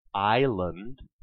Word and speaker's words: island: In Old English, the original spelling of island had no silent S.